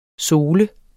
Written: SOLES

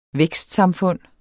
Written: vækstsamfund

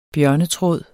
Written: bjørnetråd

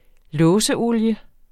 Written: låseolie

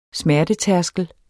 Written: smertetærskel

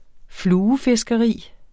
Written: fluefiskeri

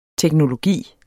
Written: teknologi

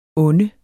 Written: onde

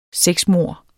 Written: sexmord

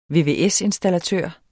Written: vvs-installatør

